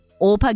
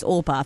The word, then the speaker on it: Orpah: Her real name, so Oprah is spelt O-P-R-A-H. Her real name is O-R-P-A-H. Orpah? Orpah Gayle Winfrey?